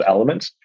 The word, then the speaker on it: LMNT: The one brand that I do like is called LMNT, P people pronounce those elements.